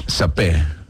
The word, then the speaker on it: saper: Yo creo que la R al final no se pronuncia, pero pues no sé. – Sapé.